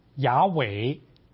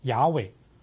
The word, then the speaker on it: 雅伟: 这个他是呢翻译出来啊，他的发音就是雅伟，特别是在以色列北方的发音就要读作雅伟。所以各位在读有一些特别是学术性的文章里面呢，他们把这个耶和华的名字啊就称作雅伟，就是从这个地方来的。